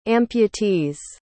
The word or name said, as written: amputees